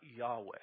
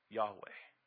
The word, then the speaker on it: Yahweh: The problem with that is there's no J sound in Hebrew. So that's not how you pronounce it. There's over 20 ways possibly we could pronounce it. But we think probably something like Yahweh.